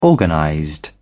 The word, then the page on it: organized: British English